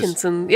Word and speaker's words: Shenzhen: Og der skal de nemlig til den by, der hedder Shenzhen, eller hvordan det nu end udtales. – Shenzhen, ja.